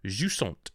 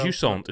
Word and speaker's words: Jusant: Jusant. And that is how you pronounce it, I've checked. – That's how I would have pronounced it as well. – Jusant. It's a French word, Jusant.